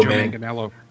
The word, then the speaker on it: Manganiello: And I guess he's being played by Joe Manganiello.